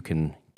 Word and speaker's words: SIE: One special thing happening, if I can talk, April 28th at the CIE Film Center. I think I put those. – S-I-E.